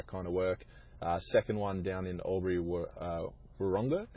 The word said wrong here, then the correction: Wodonga: Am I saying that right, Lewis? I'm not from that part of the world. – Albury Wodonga, mate.